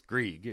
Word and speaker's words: Greig: you idiots, it's Greg." I'm like, "It's definitely not." I don't know where you got that from. It's Greig. It's, right.